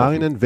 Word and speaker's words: Saarinen: Ein, ich glaube, das ist ja, richtig ausgesprochen. Saarinen,